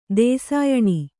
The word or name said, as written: ದೇಸಾಯಣಿ